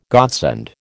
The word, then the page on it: godsend: American English